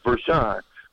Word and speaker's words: Vershan: It's pronounced Vershawn, but it's spelled in some people's eyes Vershan. You know, they, they need to see the W to be Sean.